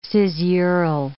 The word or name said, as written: caesural